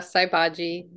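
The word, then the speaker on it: Sai Bhaji: Did I say that right? – Oh, yeah, Saibaji.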